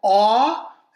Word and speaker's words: Oux: Uh, then we have a band called Awe, and that is spelled O U X.